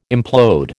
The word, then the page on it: implode: American English